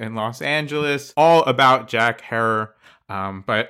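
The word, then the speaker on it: Herer: So, of course, we're talking about the legendary author and activist Jack Hare, rhymes with terror.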